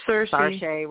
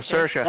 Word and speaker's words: Saoirse: Saoirse Ronan. – Oh, Saoirse.